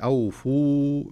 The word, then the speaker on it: أوفوا: لو يلحظ القارئ إلى المصحف يجد أن هناك ألف مستديرة وألف وألف بعدها، وهنا الألف الأولى التي في أوفوا ما تنطق، خلاص أدغمت في اللي بعدها.